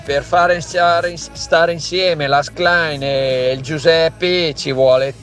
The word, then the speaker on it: Schlein: Schlein. Non è difficilissimo, anche se sì, non sappiamo il tedesco. – È un vaso di Pandora sta cosa. Stai attento. Schlein. Cominci a correggere la pronuncia.